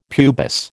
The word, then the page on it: pubis: American English